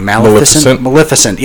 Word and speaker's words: Maleficent: I mean, just look at Disney's, I'm going to pronounce this wrong, Maleficent.